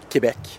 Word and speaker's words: Quebec: Am I saying it properly, Quebec? – No. – Oh. – No. – How do you say it? – Quebec.